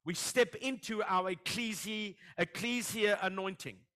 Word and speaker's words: ecclesia: The ecclesia.